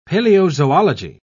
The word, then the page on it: paleozoology: British English